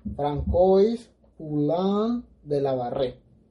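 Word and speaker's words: François Poulain de la Barre: Y pues este sacerdote era francés, nació en julio de 1647, y su nombre pronunciado en francés, espero pronunciarlo bien, es François Poulain de Lavar, pero españolizado se podría leer así, Francois Poulain de Lavarre.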